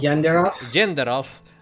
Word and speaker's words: Janderhoff: The group needs to travel to Janderhoff, however it's pronounced. – Janderhoff.